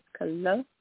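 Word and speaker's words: Kolohe: Lucky Kalo'e. Is that proper pronunciation? Kalo'e. – Yes.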